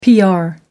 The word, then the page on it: pr: American English